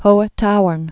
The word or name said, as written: Hohe Tauern